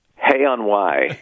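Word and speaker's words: Hay-on-Wye: Hay on Wye, is that what you said in Wales? – Hay on Wye, yeah.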